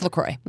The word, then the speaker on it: LaCroix: LaCroix. It's LaCroix. This is the French pronunciation.